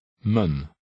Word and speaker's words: mijn: The third word is unstressed and is pronounced mun.